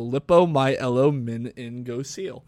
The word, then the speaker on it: lipomyelomeningocele: It says right here, pronounced lipo, L-I-P-O, my, E-L-O, which I'm pronouncing as L-O, min, in, go, seal. So lipomyelomeningocele.